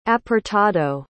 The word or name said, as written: apartado